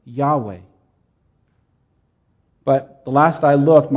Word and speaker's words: Yahweh: So they wrote it YHWH. But in order to pronounce it, we have introduced vowels and we've added an A and an E and we get Yahweh.